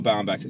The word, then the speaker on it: Baumbach: So, Noah how do you pronounce his name? – Baumbach. – Baumbach? – Mm-hmm.